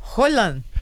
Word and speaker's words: Haaland: Haaland. – Olha, então acho melhor a gente ficar no Haaland pra não dar problema, viu? A quinta série aflora dentro da gente. Vamos de Haaland amanhã, tá bom? – Beleza.